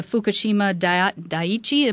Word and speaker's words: Fukushima Daiichi: Fukushima Daiichi.